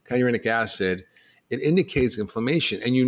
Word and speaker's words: kynurenic acid: and you end up with this byproduct called kinurate, I don't know if I'm pronouncing it right. It was Rita, I don't pronounce it. – Kynurenic acid, right. – Yeah, kynurenic acid.